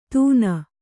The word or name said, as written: ದೂನ